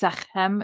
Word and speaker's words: Sekhem: So it's pronounced Sekhem. It's with the K.